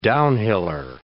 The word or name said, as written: downhiller